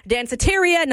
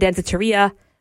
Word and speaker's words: Danceteria: So Danceteria, not Danceteria. Yeah, make sure you're saying it right.